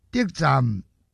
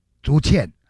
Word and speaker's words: 得沾: 无迄种命食得沾饼，得沾这个不要读成竹签啊，因为照国语的翻译就变成竹签了，不是竹签，它是得沾。